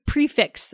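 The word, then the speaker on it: prix fixe: So I pronounce this prefix. Is that right? – Me too. – Fixed price. – I think that's right.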